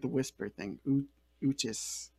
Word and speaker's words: Uchis: It's either like that or like a hungry mouse, like Cali Uchis. Oh, cheese. Oh, Uchis. – I'm just going to say Cali Uchis.